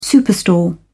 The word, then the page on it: superstore: British English